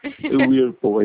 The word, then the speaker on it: Whirlpool: Whirlpool. – Whirlpool, isso, Whirlpool.